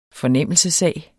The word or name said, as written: fornemmelsessag